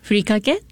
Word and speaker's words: furikake: The furikake, is that how you pronounce it? – Yes, furikake, and a dry seasoning.